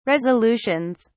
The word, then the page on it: resolutions: British English